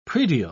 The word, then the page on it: praedial: British English